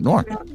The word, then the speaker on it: noir: Noir.